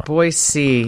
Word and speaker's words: Boise: The capital of Idaho is spelled B-O-I-S-E. – Boise. – That's how everyone pronounces it unless you live in Idaho. In Boise.